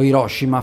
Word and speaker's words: Hiroshima: C'è una parte del tuo libro in cui tu vai a Hiroshima, o Hiroshima forse si pronuncia più correttamente,